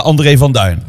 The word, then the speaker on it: André van Duin: En de juiste klemtoon. – Vandaag ben ik bij André van Duin. – Klopt.